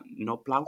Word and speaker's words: Knoblauch: How do I pronounce that? Knoblauch? – I think you got it right there.